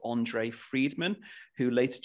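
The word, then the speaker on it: Endre: On, on, I think it's pronounced Andre. It's it's not it's E N D R E Andre Friedman.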